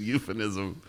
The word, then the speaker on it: euphemism: Euthanism. No, absolutely not. That's euthanizing, which is killing someone. A euphemism.